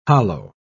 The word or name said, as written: hollow